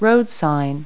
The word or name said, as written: roadsign